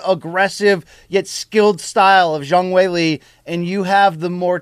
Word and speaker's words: Zhang Weili: Zhang Weili, is the correct way to say that.